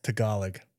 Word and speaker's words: Tagalog: That's how are you in Tagalog? What do you say? How do you say Tagalog? – Tagalog. Sorry, Filipino people.